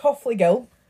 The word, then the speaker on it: Hofflegill: I laughed because it's spelt as in Jill. – It's Jill, yeah. – But it's Hofflegill. So I need to remember to say Gill and not Jill.